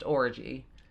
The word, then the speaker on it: Orji: Even though over here it's pronounced Orgy. – Orgy? – Her last name is O-R-J-I.